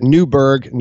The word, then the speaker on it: Newburgh: Well, Washington called a meeting of his officers and this was at Newburgh, New York, and it's spelled wrong in the notes here, but it's N-E-W-B-U-R-G-H, one word, Newburgh, New York.